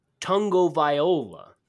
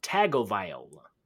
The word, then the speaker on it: Tagovailoa: It's Tagovailoa, not Tagovailoa. Tattle takes.